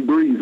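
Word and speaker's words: Breathe: Alors, prochain morceau, c'est Brief, comment on prononce Brief? – Alors, c'est Breeze, ouais.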